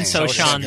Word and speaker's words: Sochan: And then they have this Jeremy Sochan kid, who was, am I saying that right? I don't even know if I'm saying it. Sochan, Sochan, they're both Sochan.